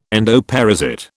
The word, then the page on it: endoparasite: British English